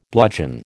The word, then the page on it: bludgeon: American English